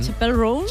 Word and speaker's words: Chappell Roan: Chappell Roan. – Chappell Roan, ¿no? – Ella misma nos explica cómo se pronuncia. – Chappell. Yes. Roan. Chappell Roan. Okay.